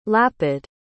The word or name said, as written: lapid